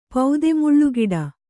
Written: ಪೌದೆಮುಳ್ಳುಗಿಡ

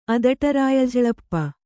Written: ಅದಟರಾಯಝಳಪ್ಪ

